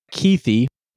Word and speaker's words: Kuithe: Brant Keithy, spelled like K-U-I-T-H-E.